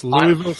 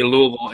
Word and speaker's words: Louisville: It's difficult to say Louisville in just one syllable. You've got to do that stroke trailing off thing, which is, you know, appropriately Southern, just kind of lull.